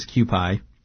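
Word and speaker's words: Kewpie: I believe the way it's pronounced is Q-pie.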